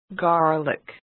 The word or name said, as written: garlic